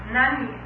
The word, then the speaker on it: Jnani: Jnani, how do you spell it? – J-N-A-N-I.